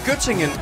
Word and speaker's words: Göttingen: Var det ved Gødringen? – Nej, Göttingen.